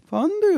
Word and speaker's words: Fondue: Fondue und Raclette. Mit der Betonung vorne.